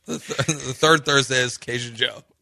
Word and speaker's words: Jeaux: It's just Joe, but it's spelled J-E-A-U-X.